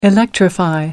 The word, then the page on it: electrify: American English